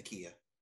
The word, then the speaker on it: Nakia: Nakia? Is that how you say it? Yeah. – Nakia. – Nakia, okay. So he's at the memorial for Nakia,